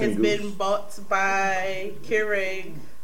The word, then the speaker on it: Keurig: K-E-U-R-G. – Yeah, that's Keurig. Yeah, that's Keurig. Keurig. – You pronounced it right.